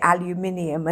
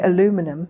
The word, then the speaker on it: aluminium: Like the big one now is like, I suppose we say aluminium and you say aluminum.